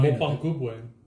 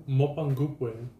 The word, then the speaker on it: Mapungubwe: No, uh, Mapungubwe. – What? Mapungu Mapungubwe.